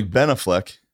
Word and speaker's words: Ben Affleck: My heart will melt if I get a text from Ben Affleck and says, hey, man, Ben Affleck.